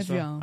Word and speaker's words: Evian: Evian. Olha Evian. Só a pronúncia francesa.